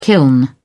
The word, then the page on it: kiln: British English